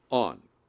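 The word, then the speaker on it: on (ἄν): There is a non-translatable Greek particle that looks like our an, A-N. That's actually how it's spelled in the Greek and pronounced on.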